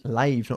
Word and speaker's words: live: Live, not live. Se escribe como el verbo to live, pero se pronuncia live cuando significa en directo, okay?